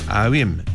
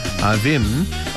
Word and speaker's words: Avvim: 먼저 아윔, 영어로는 이렇게 발음합니다. A-V-V-I-M, 특이한 스펠링이에요. 아윔, 올해 한번 잘 듣고 따라해보시기 바랍니다.